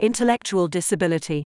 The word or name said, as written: intellectual disability